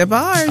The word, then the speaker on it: DeBarge: DeBarge.